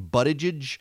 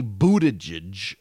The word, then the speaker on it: Buttigieg: buttigieg and you know i've been saying buttigieg but it's apparently buttigieg uh,